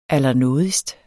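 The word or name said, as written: ALLERNÅDIGST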